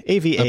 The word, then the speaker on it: Ava: I'm going with Ava. AVA is pronounced Ava.